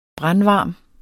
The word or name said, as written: brandvarm